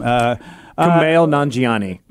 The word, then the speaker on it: Kumail Nanjiani: and I'm looking at Kunal Najani. I can never say his name. – Kumail Nanjiani. – That's the guy.